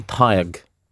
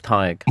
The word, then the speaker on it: Tadhg: There's a subtlety to the pronunciation of Tadhg, because it should really be Tadhg. But I'll try that next time. Tadhg. With a very soft T, almost like you're saying TH, Tadhg.